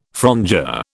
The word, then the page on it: frondeur: British English